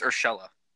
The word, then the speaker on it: Urshela: But I know it's way too early to tell, but if how do you say – I've been saying Urshela. I heard people say Urshela. – Yeah, I believe it's Urshela. Gio Urshela.